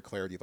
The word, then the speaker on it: Clardy: Follow me on Twitter at Troy Clardy. The last name is spelled C-L-A-R-D-Y.